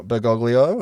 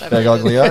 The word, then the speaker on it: Bergoglio: Jorge Mario Bergoglio, he was a bouncer. – That is how it's pronounced. – Bergoglio, that's right.